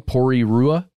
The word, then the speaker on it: Porirua: That's what's happening in several places, specifically in Auckland, New Zealand, and in this small city called Porirua. I think that's how you would say it. Forgive me if I'm wrong. Porirua. – Let's spell it P-O-R-I-R-U-A. – Yes, that is correct.